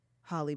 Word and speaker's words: Halle: How do you say Halle Berry? – Holly Berry. – Like H-O-L-L-Y? – Absolutely.